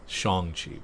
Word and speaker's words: Shang-Chi: And it's Shang-Chi, by the way. Not Shang-Chi. I gotta correct myself.